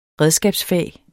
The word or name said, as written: redskabsfag